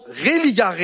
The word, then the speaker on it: religare: Le mot religion, c'est un mot latin qui veut dire, qui se prononce religare et qui veut dire relié, attaché, relation.